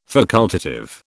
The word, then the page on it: facultative: British English